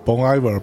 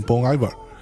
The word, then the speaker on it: Bon Iver: 对，那呃，当然也可能法语这个 Hiver 里边的 H 也可能不发音，我我猜的啊，也所以我更倾向于念念成 Bon Iver 啊。